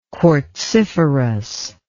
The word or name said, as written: quartziferous